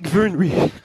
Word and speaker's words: Vyrnwy: And it's Lake Vyrnwy. – Right. – Which you've got to soften up those Ys, but that pronunciation a bit more.